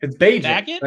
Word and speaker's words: Bagent: Is it Bagent, right? Like Bagent. Tyson Bagent. Like Bagent, like Agent of Chaos. That's why they call him Bagent of Chaos.